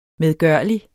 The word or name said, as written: medgørlig